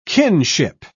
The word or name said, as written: kinship